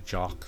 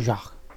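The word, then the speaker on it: Jacques: It's actually Jock. Stick it. Jock. I can't remember how to pronounce it. Jock. It's French, dude.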